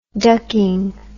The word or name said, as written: ducking